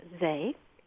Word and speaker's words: SEE: And this mixture, S-E-E, in German it's pronounced ZEE, was used to treat men who had been severely injured on the battlefield.